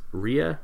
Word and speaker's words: Rea: It's Rhea, R-E-A.